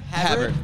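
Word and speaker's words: Håvard: Havard, yeah. It's like Harvard without the R.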